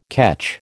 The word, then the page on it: catch: American English